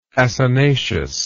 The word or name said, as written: acinaceous